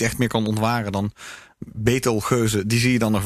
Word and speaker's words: Betelgeuze: Hoe spreek je die in het Nederlands uit? – Betelgeuze, denk ik. – Betelgeuze? – Ja. – Oké, cool. Betelgeuze, dus.